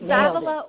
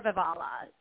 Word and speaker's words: Vavala: It's Vavala or Vavala. Either way works.